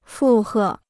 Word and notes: 附和: fù hè

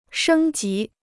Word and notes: 升级: shēng jí

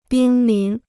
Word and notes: 濒临: bīn lín

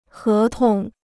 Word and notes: 合同: hé tong

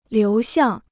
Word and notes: 流向: liú xiàng

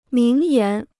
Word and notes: 名言: míng yán

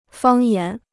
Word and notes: 方言: fāng yán

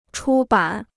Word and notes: 出版: chū bǎn

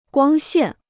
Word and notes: 光线: guāng xiàn

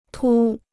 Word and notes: 凸: tū

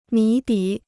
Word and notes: 谜底: mí dǐ

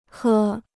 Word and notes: 喝: hè

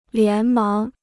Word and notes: 连忙: lián máng

